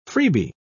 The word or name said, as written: freebie